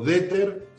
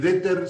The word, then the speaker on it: Dehter: Primero, José, mi, nombre es Mario Deter, se escribe Deter, se escribe como detergente, pero con una H antes de la T.